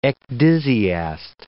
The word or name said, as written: ecdysiast